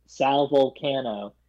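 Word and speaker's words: Vulcano: For Impractical Jokers, apparently Sal, how do you pronounce his last name? Sal Volcano. – Yes.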